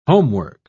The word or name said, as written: homework